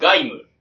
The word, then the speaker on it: 鎧武: ガイム。